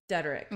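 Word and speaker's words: Dederich: I always want to say Diedrich, but I'm going to say Diedrich. – How's it spelled? – It ends with a C-H. It's D-E-D-E-R-I-C-H. Okay. So I just looked it up and it's Dederick.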